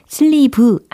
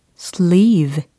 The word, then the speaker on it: sleeve: 자, 발음 여러 가지로 하고 계시는데, sleeve 아니고 sleeve, sleeve, sleeve. 장음 처리되고 있습니다. 그죠? sleeve 이게 아니고 sleeve, sleeve라고 해주셔야 되겠죠.